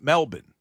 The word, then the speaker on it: Melbourne: But apparently, that's how the locals say it. It's Melbourne.